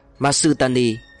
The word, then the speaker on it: Masutani: Người vẽ tấm bản đồ này ký tên là Tùng Cốc, tiếng Nhật đọc là Masutani.